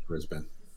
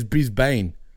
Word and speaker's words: Brisbane: Brisbane. – Oh, you said it properly for once. Every American says Brisbane.